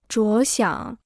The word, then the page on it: 着想: zhuó xiǎng